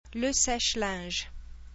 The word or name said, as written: le sèche-linge